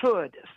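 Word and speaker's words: Chöd: Well, Caroline, it's popularly pronounced in this country as chode. So it's like could with the CH. Oh. I could do this or I could do that. It's chode, I could do this, right?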